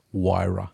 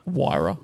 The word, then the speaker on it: Huayra: Huayra. – Huayra. – Huayra.